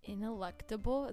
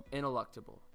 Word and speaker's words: ineluctable: ineluctable geological forces. – Ineluctable? Is that how you say it? – Yeah. – Ineluctable. – Ineluctable. It's INELUCTABLE.